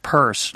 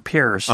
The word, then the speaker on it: Peirce: But anyway, so pragmatism, I have to confess, other than the fact that I know that it's pronounced Peirce, not Pierce, I don't know a lot about I haven't read a lot of Peirce actually.